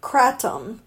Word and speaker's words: kratom: It's pronounced kratom.